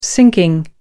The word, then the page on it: sinking: British English